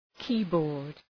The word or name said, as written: keyboard